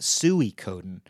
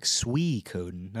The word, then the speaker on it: Suikoden: Suikoden, somebody corrected me. I used to call it Suikoden, like when you call a pig. Sui, like, Sui, get over here, pig. I guess it's pronounced like Sui. Like as if you were saying, like, sweet without the T, so like Suikoden.